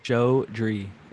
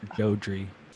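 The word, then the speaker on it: Jodrey: Is it Geordie? – Joe Dree. Even it's J-O-D-R-E-Y, yeah, but it's pronounced Joe Dree.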